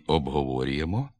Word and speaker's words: обговорюємо: Так у слові «обговорюємо», крім основного наголосу на складі «во», обговорюємо, наявні два побічні на початковому складі «об» і останньому «мо» – «обговорюємо».